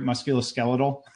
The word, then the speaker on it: musculoskeletal: Musculoskeletal? – Musculoskeletal, yeah.